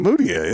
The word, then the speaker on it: Mudiay: All Emmanuel Mudiay, how do you say his last name? – Mudiay. That's how I say it.